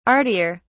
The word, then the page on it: artier: British English